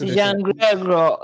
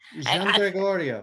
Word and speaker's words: Giangregorio: Gian Gregorio, and I had the opportunity to go to Italy, and I was taught that you would actually say the G-I-A-N as Jean, like Giancarlo, Jean-Paul, like, yes. – Gian Gregorio. – Gian Gregorio. – That's the proper Italian way, yes.